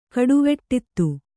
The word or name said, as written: ಕಡುವೆಟ್ಟಿತ್ತು